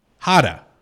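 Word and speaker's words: Jara: No, my name's David Hata. I'm the guy you're looking for. It's J-A-R-A. – How do you pronounce that? – Hata.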